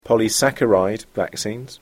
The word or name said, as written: polysaccharide vaccines